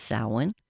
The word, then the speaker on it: Samhain: It's pronounced, it looks like Samhain in English, and it's pronounced Samhain.